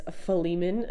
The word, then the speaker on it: Philemon: Today we are going from Philemon, which I had to look up how to pronounce that, but it is Philemon.